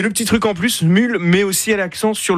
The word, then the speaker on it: Smule: Ça s'appelle Smule. Ça s'écrit S-M-U-L-E, avec plusieurs centaines de millions de téléchargements.